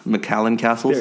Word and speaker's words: McCallum Castles: Yeah, I think for me, mine's, and I'm going to get my Scottish or Irish brethren's name wrong, but like McCallum Castles. – McCallum Castles. – There you go.